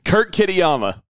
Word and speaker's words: Kurt Kitayama: Kurt Kitayama.